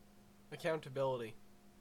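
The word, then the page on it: accountability: Canadian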